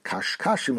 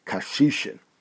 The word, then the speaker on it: kashishin: Now, in the Bir HaGola, it says that the proper pronunciation is not kashkashim over here, but is kashishin.